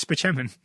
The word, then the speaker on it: Spaceman: Oh, Leo Spaceman, he's the best. – Doesn't he pronounce it Spicheman? – Oh, he definitely does. It's just Tracy calls him Dr. Spaceman.